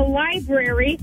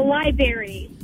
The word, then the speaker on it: library: Okay, this one's my husband, and he admittedly calls a library a library. He leaves that second R out.